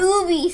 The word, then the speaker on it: Ubi: It's Ubi. Say it right.